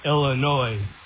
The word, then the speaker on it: Illinois: Sorry, somebody from Illinois knows how to pronounce Illinois. S is silent, by the way.